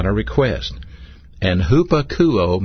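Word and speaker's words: hupakouo (ὑπακούω): Now, this particular word, obey, is a Greek word pronounced hupakouo, H-U-P-A-K-O-U-O, hupakouo.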